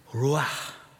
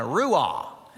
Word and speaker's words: ruach: And that word, ruach, we would pronounce it in North Carolina, ruah. That's not how the Hebrews, they would put a hard breathing, a rough breathing on that word, ruach.